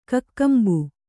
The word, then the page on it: ಕಕ್ಕಂಬು: kannada